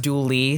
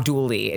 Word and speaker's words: Dula: But Dula insisted, I'm saying Dula, it's pronounced Dooley, sorry, because the Appalachian way that you right. – pronounce the A at the end is E.